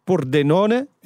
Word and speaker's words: Pordenone: Uh, Pordenone.